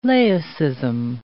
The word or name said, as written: laicism